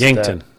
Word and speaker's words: Yankton: I don't know how to pronounce Yankton.